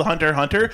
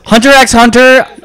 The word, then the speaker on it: Hunter x Hunter: I know it's supposed to just be called Hunter Hunter. There's an X there, and I refuse to not, like, pronounce it. – Hunter x Hunter.